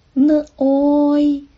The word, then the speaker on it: 内: 内容的内粤语是内，声母是呢，韵母是 oi n oi noi noi。